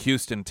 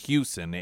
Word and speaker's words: Hewson: Should you approach Houston and say his name, people oftentimes think it's Houston, like Houston, Texas. That isn't his name. It's Houston, H-E-W-S-O-N.